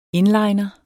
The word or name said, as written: inliner